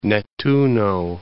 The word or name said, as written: Nettuno